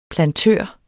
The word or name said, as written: plantør